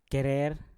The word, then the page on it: kerer: Mpur